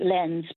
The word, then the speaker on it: Lens: and the town's spelt L-E-N-S, like Lens, but it's pronounced Lens.